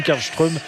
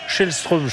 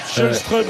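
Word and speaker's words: Källström: Juste, je fais une parenthèse sur les prononciations parce qu'on en parlait il y a un instant, mais ça me fait penser à Kim Karlström où il fallait dire aussi Shellström, je crois. – Shellström, ouais.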